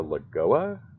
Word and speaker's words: Lagoa: There was, I guess, a little bit of a late push for Barbara Lagoa. Did I get her name right? – Yeah, in Florida, yeah.